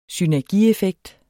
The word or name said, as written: synergieffekt